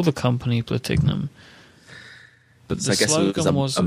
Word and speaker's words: Platignum: The brand owners added a silent G to Platignum to bypass the law.